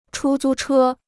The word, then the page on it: 出租车: chū zū chē